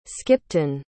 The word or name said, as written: skipton